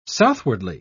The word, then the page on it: southwardly: British English